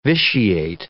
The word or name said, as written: vitiate